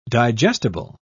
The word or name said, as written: digestible